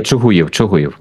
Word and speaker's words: Чугуїв: Так, а, перепрошую, у вас Чугуїв наголос на «ї»? Буду знати. – Ні, Чугуїв ми говоримо.